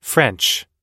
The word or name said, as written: french